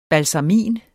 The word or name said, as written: BALSAMIN